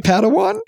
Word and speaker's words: Padawan: Padawan? – Yeah.